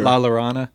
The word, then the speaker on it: La Llorona: How do you say it? Llorona? – Yeah. – La Llorona.